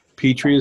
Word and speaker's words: Petry: Petry is spelled P-E-T-R-Y.